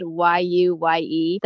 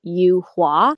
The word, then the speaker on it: Yuye: So I would tell people, even though it's spelled Y-U-Y-E, that it was pronounced Yu-Hua and that it was a flower. I just thought it sounded a lot cooler than Yu-Ye.